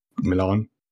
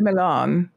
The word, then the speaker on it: Milian: Saw this this film Resort to Love, which is basically it's like a rom-com with Christiana Milian. Wait, I feel like I've just butchered her name. – Christiana. – Wait, Milian. – Did I say Milian? – You said Milan.